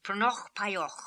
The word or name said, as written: Prnokhpaioch